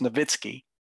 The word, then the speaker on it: Nowitzki: One is I still don't understand how people in the media, professionals, mispronounce Dirk Nowitzki's name at such a abundant level that they call him Dirk Nowitzki, which is Americanized. It's not his name. He's German. He's from Germany. It's Nowitzki. Always has been Nowitzki.